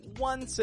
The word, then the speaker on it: once: And notice with the word once, it's spelled O N C E with an O spelling, but I pronounce it with a W sound.